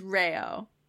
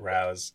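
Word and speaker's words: Rao's: I will literally never pronounce it Rao. I will pronounce it Rouse until, like, Stefano Rao shows up and tells me to change, or else he's like, yeah, exactly.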